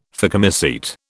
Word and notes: phycomycete: British English

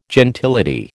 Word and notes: gentility: American English

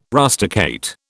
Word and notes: rusticate: British English

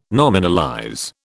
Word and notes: nominalize: British English

